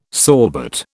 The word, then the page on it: sorbet: British English